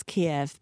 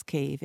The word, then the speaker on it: Kyiv: Just to explain, listeners, we know it as Kiev, but their pronunciation is Kyiv, isn't it? – That's right, yes.